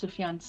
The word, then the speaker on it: Sufjan: I can't go past Sufjan Stevens.